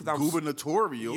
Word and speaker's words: gubernatorial: Gubernatorial. – Yeah, don't say it with your chest. – You said it, but you said it like you weren't quite sure. You I was. – Said it right.